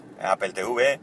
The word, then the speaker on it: Apple TV: Apple TV, TV por fin, TV lo he dicho bien, me había sonado mal, por eso he rectificado y lo he dicho en inglés, Apple TV, eh, que por fin salga algo que llame la atención y demás.